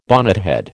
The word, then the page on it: bonnethead: American English